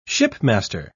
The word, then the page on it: shipmaster: British English